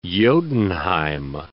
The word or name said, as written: Jotunnheim